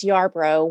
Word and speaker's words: Yarbrough: Last name is Yarbrough, Y A R B R O U G H.